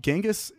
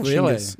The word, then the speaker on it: Chinggis: And Genghis actually is, it's Chinggis. It's pronounced, it's actually meant to be Chinggis.